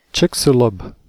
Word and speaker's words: Chicxulub: Chicxulub.